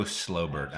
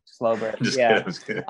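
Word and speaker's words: Schobert: And it's Joe Slowbert, actually, is how you pronounce it. – Yeah. – Slowbert.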